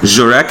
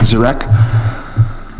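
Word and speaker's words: Guirec: So it's G-U-I-R-E-C, which I see as Jurek or Girek. Sodi, S-O-U-D-E, with one of the thangs over it.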